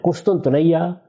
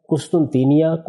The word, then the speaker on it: قسطنطنیہ: قسطنطنیہ، قسطنطینیہ، قسطنطنیہ، یہ اس کے مختلف تلفظ ہیں جو ہمارے ہاں رائج ہے تلفظ، یہ استنبول ہے۔